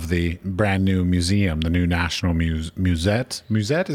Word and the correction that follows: Museet: is that how you pronounce it? – Museet. – National Museet. – Museet. Yeah. Okay, yeah. I was like, it's not a museum. It's got a T at the end.